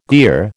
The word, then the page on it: gear: American English